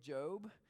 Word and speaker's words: Job: His name is Job. It's spelled J-O-B.